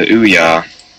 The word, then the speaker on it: Ouya: It's supposed to be the Ouya, as a, like Booyah, but without the B, according to them.